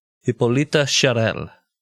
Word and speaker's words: Hippolyte Charles: It is spelled Hippolyte Charles, but it is pronounced the French way Hippolyta Charel.